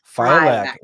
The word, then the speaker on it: Filac: Like Filac. Like lilac.